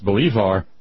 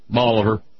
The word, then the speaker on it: Bolivar: Of course, it's not pronounced Bolivar, but Bolivar.